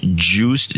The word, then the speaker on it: Joost: And then we have $200 from, you're going to have to pronounce this, from Eindhoven, Netherlands, Joost, J-O-O-S-T.